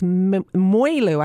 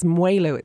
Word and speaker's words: mwelu: It's mwelu. I can't even say it. It's mwelu.